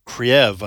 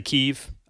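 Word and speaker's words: Kyiv: I found out that Kiev was the pronunciation under the Soviet Union, mm-hmm. and so a lot of Ukrainians don't like that pronunciation. So Kiev, I like Kiev. That's a little bit easier to remember.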